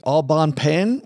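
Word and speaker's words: Au Bon Pain: Also another company, Aubon Pen, which I probably slaughtered the pronunciation of that, but I think we've all seen.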